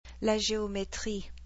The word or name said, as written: la geometrie